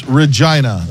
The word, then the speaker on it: Regina: It's Regina. That's what? – The way it's pronounced.